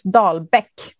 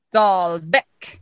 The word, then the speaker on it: Dalbäck: So in Swedish, because it's spelt with the umlauts on one of the A's. So it's Dalbäck. – Oh! – Dalbäck.